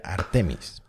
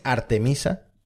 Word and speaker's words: Ártemis: Amigos, se han anunciado por fin los tripulantes de la misión Artemis 2, que también he aprendido que no se pronuncia Artemis. O sea, o dices Artemisa, que es el nombre en castellano, o lo pronuncias Artemis, que es como supuestamente eh, se debe de pronunciar.